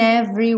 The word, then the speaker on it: every: O é do meio da palavra every não é pronunciado, então a gente não diz every, e sim every.